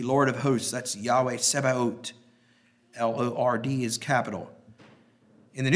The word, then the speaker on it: Sabaoth: Number 9 is Yahweh Sebaot. That word looks almost like Sabbath, but it's pronounced very much differently. I'll write it here. – S-A-B-A-O-T-H. And as I understand, the H is silent. Sebaot.